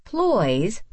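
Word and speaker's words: ployes: Not quite crepes, ploys, which rhymes with boys,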